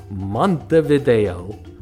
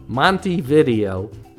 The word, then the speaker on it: Montevideo: If we have a romantic vision of this place, it's because we mispronounce it with flair. Montevideo. The locals call it Montevideo, making it sound more like an 80s shop where a guy named Monty rents VHS tapes.